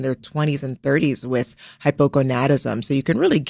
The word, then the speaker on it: hypogonadism: So late onset hypogonadism.